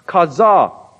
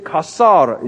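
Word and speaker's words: caza: And thousands of Puerto Ricans understood the warning to be all Kasar, C-A-S-A-R, would be prohibited. He mispronounced Kaza and he said Kasar instead.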